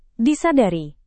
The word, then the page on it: disadari: Indonesian